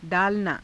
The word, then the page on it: _daalanaa: Hindi